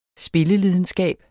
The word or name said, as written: SPILLELIDENSKABEN